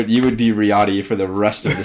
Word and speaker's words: Riyadh: It's Riyadh.